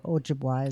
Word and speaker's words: Ojibwa: One of them is I would love to spend time with the Chippewa, or Ojibwa is the correct way to say that, Indian tribe, and learn all the spiritual things that, you know, were taught years and years and years and years.